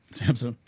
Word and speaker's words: SAMHSA: How do you say that? – SAMHSA. – Yeah, SAMHSA.